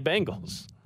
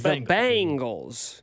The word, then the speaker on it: Bengals: And that became the sounder we used, because that is Walk Like an Egyptian, sang by the Bengals. – And Cody would say Bengals. – Yes, so he pronounced it like the 80s band, the Bengals.